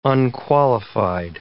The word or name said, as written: unqualified